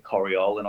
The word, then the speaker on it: Corioli: made by Corioli. Well, we pronounce it Coriol in Australia, but I can't help myself. I'm going to pronounce it Corioli because I think it's Italian.